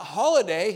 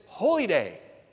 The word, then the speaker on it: holy day: But Rabbi Schneider said, he corrected me on the pronunciation, not holiday, he said it's holy day.